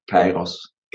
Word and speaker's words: Kairos: Ich habe, glaube ich, Karios gesagt und es heißt... – Ja, Kairos.